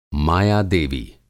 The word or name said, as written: माया-देवी